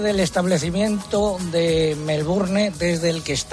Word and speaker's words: Melbourne: ¿Cómo se pronuncia en inglés? Que yo estoy peleado con esta lengua bárbara. – Melbourne. – Melbourne.